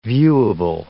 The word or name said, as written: viewable